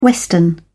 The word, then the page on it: western: British English